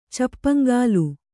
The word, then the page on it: ಚಪ್ಪಂಗಾಲು: Kannada